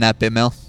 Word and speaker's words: Nat Baimel: I'm Nat Baimel. – Nat Baimel. I've been saying it wrong for the last hour. – Or 3 years. – Or calling him Nate Baimel.